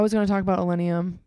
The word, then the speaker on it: Illenium: So I took the day off on Monday because we went to Illenium. Is that how you say it? – Yep.